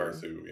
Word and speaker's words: Carthew: Dakota and I interviewed the director, uh, Kirsten uh, Carthew. – Carthew, yeah. – Carthew, is, I think that's how you pronounce it.